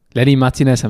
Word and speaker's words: Lenny Martinez: Lenny Martinez haben wir richtig ausgesprochen.